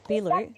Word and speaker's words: Beelu: B-E-E-L-U. – Yeah, okay. Beeloo.